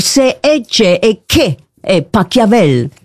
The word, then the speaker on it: Pachelbel: Catelloni de Pachelbel. – S'il est allemand, ça se dit Pachelbel.